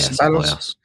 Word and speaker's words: Ceballos: Sabellos or Cabellos, how you want to say his name? – Yeah, Sabellos.